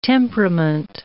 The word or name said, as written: temperament